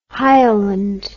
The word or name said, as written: hieland